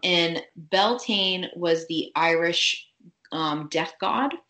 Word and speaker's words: Beltane: again, a couple different spellings, different pronunciations. Do you say it the same way I do? I say Beltane.